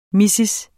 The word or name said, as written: mrs.